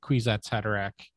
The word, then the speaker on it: Kwisatz Haderach: If anything, John has, I've always said that John embodies the Kwisatz Haderach Thank you. Good pronunciation.